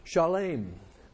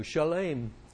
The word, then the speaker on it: Shalem: And it says in Melchizedek, king of Salem, Shalem, which is the way it's pronounced in Hebrew, Shalem, brought forth bread and wine, and he was the priest of the Most High God.